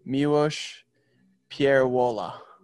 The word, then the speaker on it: Miłosz Pierwola: I am your host, and today we are having on Miłosz Pierwola. Did I say that right? – Close enough.